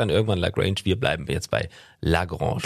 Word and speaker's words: La Grange: Ja, ob Lagrange oder Lagrange spricht man überall anders aus. Wir sagen Lagrange, weil es einfach, ja, ein bisschen, äh, ja, wie soll man das sagen, Benny? – Ja, erotischer klingt.